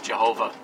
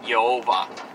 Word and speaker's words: Jehovah: Some of the places in the Old Testament and the King James that use Jehovah, which is Germanicized letters pronounced wrongly to get Yehovah.